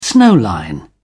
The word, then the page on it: snow line: British English